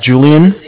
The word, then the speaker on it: Julian: My name's Julian, J-U-L-I-A-N.